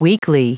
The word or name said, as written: weekly